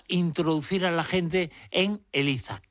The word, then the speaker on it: ELIZA: ELIZA, y además está bien pronunciado con Z, aunque yo vivo en Cádiz, no es que sea ELIZA y yo le llame ELIZA, que ya me lo han dicho por ahí, no, es ELIZA con Z.